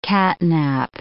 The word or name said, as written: catnap